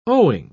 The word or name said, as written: owing